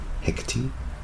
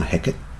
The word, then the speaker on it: Hecate: called Hecate, or as it's pronounced in Noongar, Hecate, named after the witch at yes.